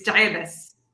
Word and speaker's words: Dabbas: Just the last name. It's Diabis. – Diabis. But it's D-A-B-I-S though, right? – Yeah.